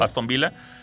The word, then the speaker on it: Aston Villa: Sevilla, Villarreal y Aston Villa, que se escribe Villa, ¿no? Pero Aston Villa.